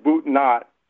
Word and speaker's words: Boudinot: Uh, when you first go into Princeton, you'll see a street called Boudinot, or as you locals pronounce it, Boot Not.